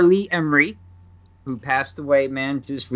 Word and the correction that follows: Ermey: Ermey. – Yes. – Ermey. I hate they say that name wrong. Ermey. – It's Ermey. Okay, Ermey.